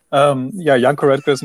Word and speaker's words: Janko Roettgers: So, Janko Roettgers is my name again.